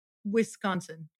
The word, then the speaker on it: Wisconsin: Did I say that right? – Wisconsin. Give it a try.